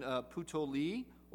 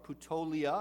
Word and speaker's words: Puteoli: And then Puteoli, or Puteolia, I've heard it pronounced both ways, is the port on the coast of Italy from which you would walk the rest of the way to Rome.